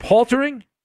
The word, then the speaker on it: paltering: It's called, I believe that you pronounce it paltering, P-A-L-T-E-R-I-N-G.